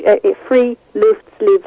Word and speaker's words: Friluftsliv: And in 1859, Heinrich Ibsen, the playwright, said, he coined the term Friluftsliv, which Friluftsliv, that's three syllables, and free is fresh, and luft is air, and leave is life.